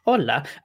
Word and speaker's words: hola: You don't pronounce the H's in Spanish, Adam. God. Hola. Yes, intangibles for the whole WrestleTalk family.